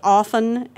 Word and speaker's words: often: I guess, well, you know, that's kind of like the words often and salmon. – Mm-hmm. – There are words that you're supposed to ignore. – Yeah, letters you mean? – Letters, right. The T in often shouldn't be pronounced.